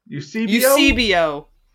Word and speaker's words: Eusebio: Yeah, how do we pronounce his name? – Esuebo. – Can we get that out of the way? – Eusebio? – Is it Eusebio? – Eusebio? – Eusebio. – Like C-3PO.